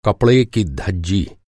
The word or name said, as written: धज्जी